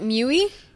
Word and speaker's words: MIUI: Is that how you pronounce it? MIUI? – MIUI. Yeah, is MIUI.